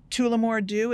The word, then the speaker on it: Tullamore Dew: Tullamore Dew, is that it? – Yes. – Okay. – Yep, that's right.